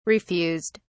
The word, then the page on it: refused: British English